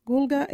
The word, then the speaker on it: gurlga: Gulga. Gulga is your ear. You can use Gulga in this sentence. Hey, you got a big Gulga. Or, poor fella got a sore Gulga. Gulga is spelled G-U-R-L-G-A.